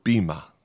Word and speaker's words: bema: The Greek word translated as judgment seat is the word bema. Okay, it's spelled B-E-M-A.